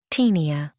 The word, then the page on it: taenia: American English